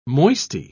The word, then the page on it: moisty: British English